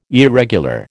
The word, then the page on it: irregular: American English